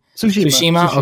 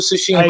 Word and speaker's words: Tsushima: Tsushima. – Okay. – Yeah, I ignore the T. – Did you? Here. Okay, perfect. Ghost of Tsushima. – I ignore the T completely.